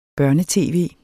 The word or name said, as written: børne-tv